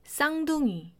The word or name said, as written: 쌍둥이